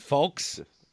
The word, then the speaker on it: folks: Well, you don't pronounce the L in folks. – Folks? – It's just folks.